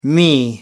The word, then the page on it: me: American English